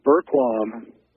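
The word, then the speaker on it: Bergquam: It's Burquam.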